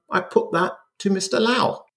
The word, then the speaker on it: Lau: It's William Lau, which rhymes with Leslie Chow.